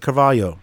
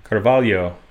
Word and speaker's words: Carvalho: Yeah, you pronounce it Carvalho. – Carvalho.